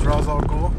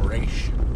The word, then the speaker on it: Ra's al Ghul: I'm pretty sure it's supposed to be pronounced Ra's al Ghul. But then on my Batman game, they call him Ra'sh. – Ra's al Ghul. I'm like, pretty sure it's Ra's al Ghul.